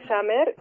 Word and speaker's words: Zamher: Sí, claro, me pueden encontrar como Nancy Summer, el apellido se escribe Z-A-M-H-E-R.